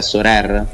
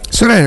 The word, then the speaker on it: Sorare: Che oltre al comunicato di ieri dell'accordo della Lega con Sorer, sai cos'è Sorer? – Sorer è quel giocatore del Perugia, no? – Perfetto. che poi si scrive Sorare.